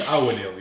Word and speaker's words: Ouweneel: Did I say that right? – Yeah. I gave up already. But it's Ouweneel, yeah.